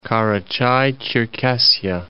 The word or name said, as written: Karachay-Cherkessia